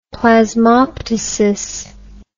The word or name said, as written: plasmoptysis